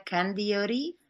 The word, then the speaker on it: Candiotti: Well, this was Linda Candiotti from, how do you say your name? Say That's it. Right. You said it just right. Candiotti.